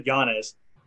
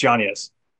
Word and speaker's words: Yanez: So I always said Yanez, but Luke Thomas corrected me this week. I was watching Luke Thomas's shit this week, and it's Yanez. That's correct pronunciation.